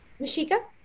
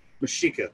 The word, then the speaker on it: Mexica: Is it Mexica or Mexica? – Mexica. – Mexica, thank you.